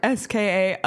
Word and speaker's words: SKA: Do you say SKA or do you say SKA? – You pronounce the letters. – Okay. St. Petersburg, SKA of the KHL.